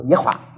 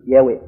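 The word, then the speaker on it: Yahweh: 中文的耶和华，我就想你老是把那猴子读得快一点，差不多耶华耶华耶华啊耶华。可是比较准确的读音是耶维耶维，应该是这样。现在许多人他都念的时候都耶维，他把那个重音放在第一个字，希伯来文的平常的时候这个重音中间没了一个字，所以耶维应该是一个准确的读音。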